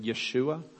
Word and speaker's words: Yeshua: Probably the Hebrew pronunciation or the Aramaic pronunciation of it would have been Yeshua.